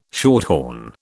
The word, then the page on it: shorthorn: British English